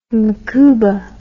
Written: macouba

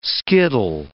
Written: skittle

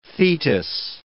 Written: Thetis

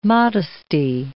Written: modesty

